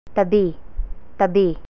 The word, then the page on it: tabi: Tagalog